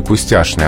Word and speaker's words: пустяшный: Но что еще примечательно в слове пустяшный? Оно ведь одно из немногих оставшихся слов, где пишется «чн», а произносится «шн». Раньше таких слов было больше, их количество неуклонно снижается.